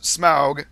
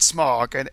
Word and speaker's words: Smaug: And it is pronounced Smaug, not Smog, and has nothing to do with pollution.